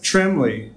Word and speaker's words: Trembley: Today we have Jeff Trembley.